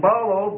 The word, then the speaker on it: ballo: Dia, D-I-A, which means through, going through the door, through the window, going through. And then balo, B-A-L-L-O, it's a long O, which means to throw or to cast.